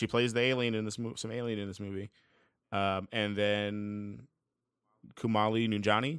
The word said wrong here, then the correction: Kumail Nanjiani: is that how you say his name? – Kumail Nanjiani.